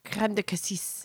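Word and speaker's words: crème de cassis: We use their creme de cassis.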